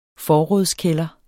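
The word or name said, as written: FORRÅD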